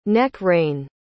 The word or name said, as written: neck-rein